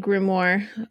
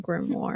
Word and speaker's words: grimoire: Grimoire is a word that kind of looks like, or it has a wonky pronunciation, because like Samhain, when you look at the word Samhain, it looks like Samhain, you know? Mm-hmm. And when you look at grimoire, it, I don't even know what that would say. Grim doesn't look like grimoire. – It's like, it's spelled like French, right? Like, and the it is a French word. Okay, it is a French word. It comes from French background. – It's like O-I-R-E or something.